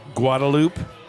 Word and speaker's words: Guadalupe: So come out to the dive bar off Guadalupe.